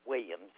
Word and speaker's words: Williams: Carolyn Williams. – Could you spell that for me, please? – C-A-R-O-L-Y-N. W-I-L-L-I-A-M-S.